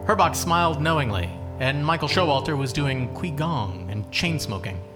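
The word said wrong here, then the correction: qigong: It's pronounced chi-gong.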